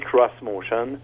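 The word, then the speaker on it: X-Motion: Bon, ça s'écrit X motion. D'accord mais on insiste pour prononcer cross motion.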